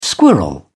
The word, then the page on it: squirrel: British English